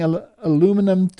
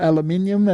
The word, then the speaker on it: aluminium: And chemtrails and adding aluminum to the ground, or aluminium as it is pronounced in other countries and regions, is not conducive to a good environment.